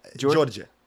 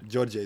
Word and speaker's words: Djordje: Georgia. – Georgia. – Georgia is my name.